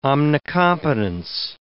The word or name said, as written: omnicompetence